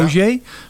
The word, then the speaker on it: Hozier: Ik heb zelf het idee Mumford Sons en Hozier. Hoe zullen we het uitspreken? – Ik, ja. Hozier.